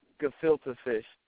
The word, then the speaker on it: gefilte fish: That was some of that Gilfit fish. Am I pronouncing that right? Okay, what is a Gilfit fish, first of all? – It's a gefilte fish.